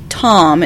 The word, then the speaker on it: Thom: And it starred, who I think you pronounce his name, Tom. It's T-H-O-M. Tom Bray. – Yeah, I did not pronounce it Tom. – Yeah. – I pronounced it Thom.